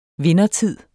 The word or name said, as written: vindertid